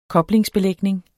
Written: koblingsbelægning

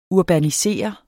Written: urbaniserende

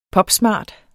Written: popsmart

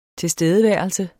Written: tilstedeværelse

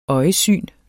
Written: øjesyn

